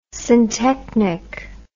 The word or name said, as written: syntechnic